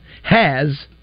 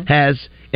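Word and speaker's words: Hasz: Haz, which is H-A-S-Z, I believe it's spelled Haz. Mm-hmm. Haz.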